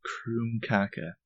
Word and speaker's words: krumkake: Did you do the research on the pronunciation? – Yes. This is a Freiwald family treat as well. I did do the research. – Kroom kaka. – I'll even help. It's a Norwegian pastry. – K-R-U-M-M-K-A-K-A. – K-R-U-M-K-A-K-E? – I said K-A-K-A.